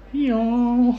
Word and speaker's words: EO: It's called EO. Letter E, letter O, EO. – Mm-hmm. – It's about a donkey. – Oh, that's fun. – Yeah. – So it's pronounced EO.